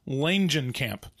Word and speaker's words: Langenkamp: Do you pronounce the J? The G? – Yes, I believe you do. – Langenkamp. – Langenkamp.